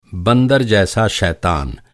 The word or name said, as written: शैतान